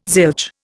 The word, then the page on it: zilch: Indian